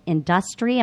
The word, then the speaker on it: INDUSTREE: There's a new industry networking group that recently launched in Atlanta called Industry. Am I saying that right? – Industry, but it's spelled INDUSTREE. – Correct.